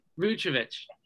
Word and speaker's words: Vucevic: Vucevic? – Vucevic.